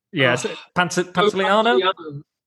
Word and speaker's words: Pantoliano: Pantoliano.